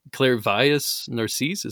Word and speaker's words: Clairvius Narcisse: Clairvius Narcisse, is that correct? – Mm-hmm.